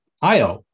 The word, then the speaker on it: Io: I say further out in the solar system, we have Io, or Io, depending on how you pronounce it, one of Jupiter's moons.